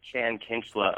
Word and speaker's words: Chan Kinchla: I'm very excited to be here with Chan Kinchla of Blues Traveler, the lead guitarist of Blues Traveler.